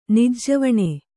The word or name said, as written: ನಿಜ್ಜವಣೆ